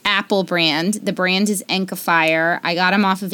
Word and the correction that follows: Enacfire: Oh, Enakfier. That's how you say it.